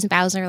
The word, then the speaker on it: Bowser: It's just Sarah Lynn Bowser. And it is Bowser, like the Mario character.